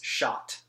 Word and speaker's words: Schott: I just S C H O T T. – Yeah, it's shot.